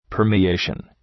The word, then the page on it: permeation: ‘pɜ:rmı,eıʃən